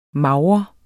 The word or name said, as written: mager